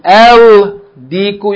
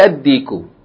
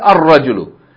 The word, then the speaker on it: ad-diku: You double the first letter, if it's a solar letter. And instead of pronouncing the Lam, instead of saying Al-Diku, you're going to say Ad-Diku. Instead of saying Al-Rajulu, you're going to say Ar-Rajulu.